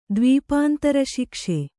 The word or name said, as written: ದ್ವೀಪಾಂತರಶಿಕ್ಷೆ